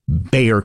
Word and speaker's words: Bexar: Well, San Antonio, Texas sits in the county B-E-X-A-R, pronounced Bayer County. – Yeah. – B-E-X-A-R.